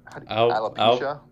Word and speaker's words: alopecia: Alopecia? – I thought it was...